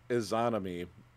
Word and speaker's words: Izanami: And uh, it was created by Izangi and Inanami, I, I, I totally said that wrong. Izanami, um, the god, uh, Shinto god and goddess.